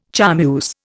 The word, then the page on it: charmeuse: Indian